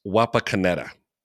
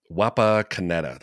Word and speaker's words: Wapakoneta: And you can tell me how bad my pronunciation was, but I'm going to say it's Wapakoneta. Wapakoneta. That sounds pretty good.